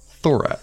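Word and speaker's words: Thorac: So thank you to, I'm going to go with Thorak. – How's it? – What is it spelled? – It's spelled Thor, T-H-O-R, followed by A-C. Thorak.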